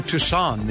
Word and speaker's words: Tucson: Pronounce this city, if you would, T-U-C-S-O-N. – Oh, it's Tucson, of course.